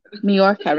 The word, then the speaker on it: Mallorca: Only, well, I have to say, like, the night there, I was preparing to go away on my holiday to Maloka. How do you say Menorca? Is it Menorca or Maloka? Mallorca, right? So now that we're in Mallorca, I was getting myself organised for it.